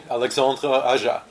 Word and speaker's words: Alexandre Aja: Alexandre Aja.